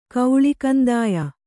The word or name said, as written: ಕೌಳಿಕಂದಾಯ